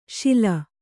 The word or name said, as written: ಶಲೆ